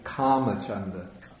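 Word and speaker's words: kāmacchanda: So we can hear the correct pronunciation of the Pali words here, like for kamachanda. And yeah. – Yeah. Okay. I'm never sure. So. – Yeah. It's actually kamachanda.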